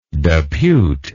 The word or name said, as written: deputable